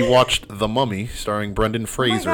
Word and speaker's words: Fraser: And just for the record, it's pronounced Fraser, not Frasier.